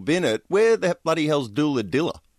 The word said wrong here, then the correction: Doolandella: Did I pronounce that right? – Doolandella. – Doolandella, is it? – Yeah, Doolandella, that's correct.